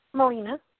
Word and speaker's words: Melina: Molina. – Molina? – Yes. – How do you spell it? – M-E-L-I-N-A.